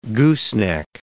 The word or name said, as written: gooseneck